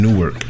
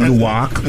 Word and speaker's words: Newark: Is that how you say Newark? Because I know people from Newark always be like, Newark. – Don't say it like that. – Nah, Newark. Newark. Am I saying it right? – Newark. – Newark, because they don't want to get it. They want to make sure they want to decipher it from New York.